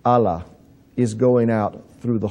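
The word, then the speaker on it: Allah (אלה): It's spelled Aleph Lamed He, curse. It's pronounced Allah. Now, the word or the name of the God of Islam would be spelled Aleph Lamed Lamed He, but it's pronounced exactly the same way.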